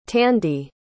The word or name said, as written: tandy